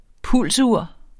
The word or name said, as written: pulsur